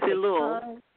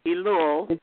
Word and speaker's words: Elul: Now, understand there is a period of 40 days before Rosh Hashanah, and I'm not going to pronounce this right, but it's spelled E-U-L-U. So help me out, guys. – And it's Elul. – Yes. – Elul.